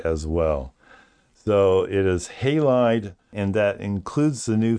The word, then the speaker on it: Halide: And the way that he says it's pronounced, and I think he is absolutely correct, is Halide. Halide is the app, and I was not saying it that way.